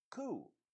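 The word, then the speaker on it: coup: Now, that's a French word, like a lot of our words come from the old Latin, the French, and various languages, and it's pronounced coo, like if you heard a bird going coo, coo, you know?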